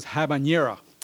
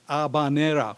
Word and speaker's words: habanera: Some of you may know a musical style known as the habanera, or often mispronounced as habanera, which, you know, is, I don't know where they got that mispronunciation, but it's not that, it's habanera, which means it comes from La Habana, which is Havana, right?